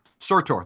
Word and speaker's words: Surtur: But I want to begin with the opening of Thor Ragnarok, where we meet the giant, the fire giant Surtur, voiced of course by Clancy Brown, and Thor. – Surtur. – Thank you. See, you're gonna have to help me with these Norse pronunciations.